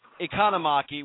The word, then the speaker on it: Economaki: And unfortunately, we're talking about the passing of Chris Economaki. How do you say the last name? – Economaki. – Economaki. I got it right now.